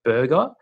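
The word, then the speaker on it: Burgher: his race is actually called a burger. It's spelled a little bit different to, you know, the burger that you eat, but sounds the same. It's got an H in there.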